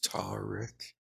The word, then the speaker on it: tarik: There's the classifications of reasons, which is tar-rick, T-A-R-I-K. It's basically my name, because the T is pronounced as a D.